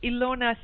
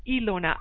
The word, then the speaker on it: Ilona: Well, go to Amazon, type in my name, Ilona Selke, and it's spelled with an I. So remember, I like you and me and I, Ilona, I L O N A, Ilona Selke, S-E-L-K-E dot com or Ilona Selke on Amazon.